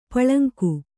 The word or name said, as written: ಪುಲ್ಲಿಂಗ